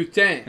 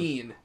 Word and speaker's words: poutine: Poutine. It's not just pretentious because they're saying it right. It's like, yeah. – They're pronouncing it how it's supposed to be pronounced.